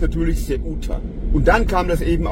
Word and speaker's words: Ceuta: Zunächst mal habe ich den Ort falsch ausgesprochen. Ich sagte Ceuta. Das heißt natürlich Ceuta.